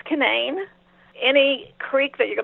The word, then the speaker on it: Canaan: Everybody calls it Canaan Valley because in the Bible it's pronounced Canaan.